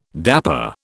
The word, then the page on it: dapper: British English